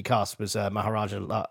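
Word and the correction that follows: Mahershala: Ali. I can never say his name. – Maharshala, that's it.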